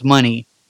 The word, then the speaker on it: Monet: Uh, her ring name was changed, of course, they can't leave good thing alone, to Frankie Monet. And actually, it's pronounced Money, Frankie Money, and began to work in the NXT television program.